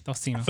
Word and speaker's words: tocino: No, it's like T-O-C-I-N-O. – Tocino? – I don't know if it's with a s, though, or a k. – Beto is. – Is it a Eric? – It's a s.